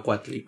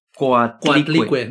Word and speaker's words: Coatlicue: Casualmente la Virgen de Guadalupe se parece a Cuatli, güey. Casual, casual. Casualmente la Virgen de Guadalupe apareció en el cerro donde los mexicas adoraban a Cuatli. Casualmente. – ¿Cómo se pronuncia? Coatlicue.